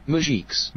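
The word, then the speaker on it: muzjiks: Muzhiks. – Muzhiks. Okay. So it's not musics. It's muzhiks.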